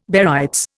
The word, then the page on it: barytes: Indian